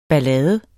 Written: ballade

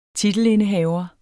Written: titelindehaver

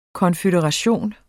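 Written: konføderation

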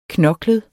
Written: knoklet

